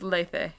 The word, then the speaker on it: Lethe: It's pronounced Lethe in Greek. But no one in English ever pronounces things the way that they're pronounced in Greek.